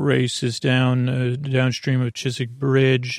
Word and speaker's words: Chiswick: Chiswick, C-H-I-S-W-I-C-K.